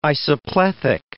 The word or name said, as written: isoplethic